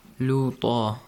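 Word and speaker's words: لوط: If you make a pause, how do you read it? – لُوطَ.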